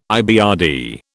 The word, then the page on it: IBRD: British English